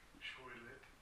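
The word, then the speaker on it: Sylhet: Probably get a more authentic pronunciation there. – Shailet.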